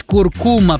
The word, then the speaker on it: куркума: Несмотря на то, что тоже большинство произносит данное слово с ударением на последний слог, акцент в нем все же стоит на втором. Правильно говорить куркума, представляете?